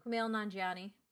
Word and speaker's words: Kumail Nanjiani: And then Kumali Nanjiani, is that how you say his name? – Kumail Nanjiani.